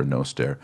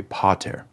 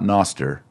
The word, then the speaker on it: Pater Noster: So that A on Pater is not going to be short. It's going to be a long Latin A. We've discussed what that means already. So make sure you've seen that previous unit. It's not Pater with a short A. It's a long A, Pater. pater. Same with noster. It's a long O. Noster, not noster. Noster. So pater noster.